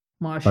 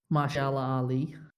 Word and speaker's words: Mahershala Ali: Did you actually pronounce his name right? – Marsha. – I don't think. I was going to say, I don't think it's actually Marsha Ali. – Mahershala Ali.